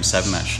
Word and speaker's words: 7mesh: But the pronunciation of Seven Mesh is a guttural stop, mesh. But I'm assuming you're not. – Yeah, we pronounce our brand name Seven Mesh,